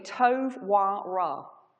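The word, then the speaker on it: tov, wa, ra (טוב ורע): So good and evil in Hebrew, which is what the language Genesis would have been written in, or was written in, is pronounced approximately tov, wa, ra.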